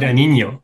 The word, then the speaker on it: Guadagnino: Und dann noch von Luca Guadagnino, habe ich das richtig ausgesprochen? – Guadagnino, ja.